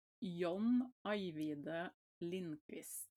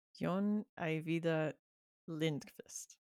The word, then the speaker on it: Jon Ajvide Lindqvist: Because I've been it's Jon Ajvide Lindqvist. – Jon Ajvide Lindqvist.